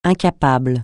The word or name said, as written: incapable